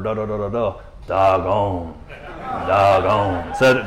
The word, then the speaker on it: Dagon: It's actually named Dagon, which I think is just hilarious, right? You know? And so Dagon is how you say it.